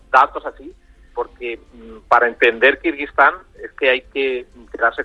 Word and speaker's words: Kirguistán: Tú lo has pronunciado muy bien, Carlos, pero te aseguro que toda la gente ahora que le he dicho dónde me iba le ha costado millones pronunciar Kirguistán, porque claro, hay tantos tan, Kazajistán, Uzbekistán, Tayikistán, que se perdían un poco incluso con la pronunciación.